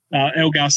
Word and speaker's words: Elgar: Dean Agar, Elgar, sorry.